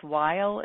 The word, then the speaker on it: Weil: It's a whole new program that we're starting with Dr. Andrew, I believe it's Weil is how you pronounce his name.